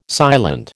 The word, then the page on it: silent: American English